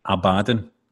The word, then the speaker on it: Abaddon: Are the Fiend and, how do you say to pronounce this, by the way? Because I pronounce it right, Abaddon. – Abaddon.